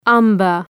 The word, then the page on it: umber: ‘ʌmbər